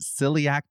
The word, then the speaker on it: celiac: If you have, is it celiac disease? Is that how you pronounce celiac? – Celiac.